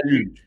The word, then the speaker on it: luge: Sí, luge, está bien. Es una palabra francesa que quiere decir sled, quiere decir trineo. Sí. – Y, eh, la luge.